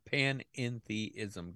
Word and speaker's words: panentheism: It's called panentheism.